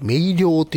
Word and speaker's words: メイリオ: で、あと、よく使われてるのが、メイリオ。ね、僕、メイリオって読むのかなと思ったんですけども、メイリオって読むそうですね。